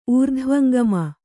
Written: ಊರ್ಧ್ವಂಗಮ

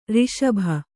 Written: ಋಷಭ

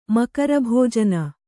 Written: ಮಕರಭೋಜನ

